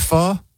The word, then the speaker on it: pho: Oh, you pronounce it pho? – It's pronounced pho. – Even though it's spelled pho? – Yes.